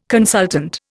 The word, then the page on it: consultant: Indian